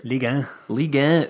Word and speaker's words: Ligue 1: Wren currently sitting in second place, and how do you pronounce that French league? – Ligue 1.